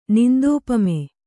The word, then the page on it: ನಿಂದೋಪಮೆ: Kannada